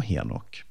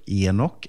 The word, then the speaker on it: Henok: Och Henok kan kallas ibland för Enok eller Hanok, beroende på vilket språk och vilken bibelöversättning man läser. Men jag har valt det uttalet som används i Folkbibelns 2015-översättning. Och Henok han nämns framförallt i Första Mosebok 5 i vers 18-24.